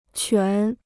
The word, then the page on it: 全: quán